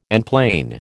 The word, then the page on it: enplane: American English